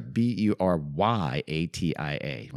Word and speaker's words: Buryatia: Yes, I know there's a thing called Google pronunciation, but as Burayasha, B U R A, I'm sorry, B U R Y A T I A. Want me to do it real quick? Our story takes place in a place called Buryatia.